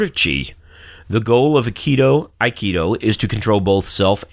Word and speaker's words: Aikido: And tell me if I pronounce these wrong. Aikido? – Aikido. – Aikido. Aikido. Okay.